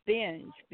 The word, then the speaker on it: Benge: It's funny you mentioned that, Tommy, because my maiden name was Benge, B-E-N-G-E, and mm-hmm. everybody pronounced it Benji. – Okay. – And or Ben Gay. It'd be Ben Gay. – No, it wasn't Ben Gay. They always pronounced it Benji. And my dad's oldest sister, when she was in school, every year, the teacher would always say Benji. And she'd say, no, it's Benj.